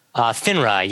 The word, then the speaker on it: FINRA: FINRA, yeah, the Financial Industry Regulatory Authority.